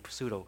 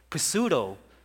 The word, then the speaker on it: pseudo: The English pronunciation, we don't pronounce the P word, so it ends up being pseudo. But Greek word, actually, they pronounce everything. So pseudo, or as plural, is pseudos, means it's lie.